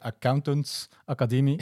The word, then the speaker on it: Accountants Academie: Nee, en dan eh, sluiten we af met eh, de halve hastgeer van deze eh, aflevering, Accountants Academie. – Oh, opnieuw goed uitgesproken, echt.